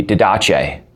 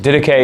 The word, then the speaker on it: Didache: If the Didache were a person and he were Italian, his name would be Didache. But that's just a bad pronunciation, in fact. Didache is how you say it.